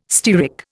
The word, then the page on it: stearic: Indian